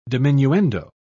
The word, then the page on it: diminuendo: British English